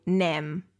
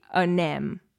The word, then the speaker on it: anem: I sí, per exemple, això passa amb el verb anar moltes vegades sentiràs que algú diu nem, nem, en comptes de dir anem.